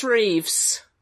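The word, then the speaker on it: Roëves: I think one of the best performances in it actually is from Maurice, I can never pronounce his name, Roves, the guy that plays Stotts. – Oh, Maurice Reeves.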